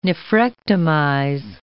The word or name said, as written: nephrectomize